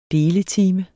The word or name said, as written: deletime